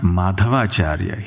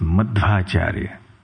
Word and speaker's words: Madhvacharya: Madhvacharya, the name is often confused as Madhavacharya, but they're two different words, actually. He is not Madhavacharya, he is Madhvacharya, not the long A.